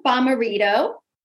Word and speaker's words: Bommarito: We have Dr. Sam Bommarito.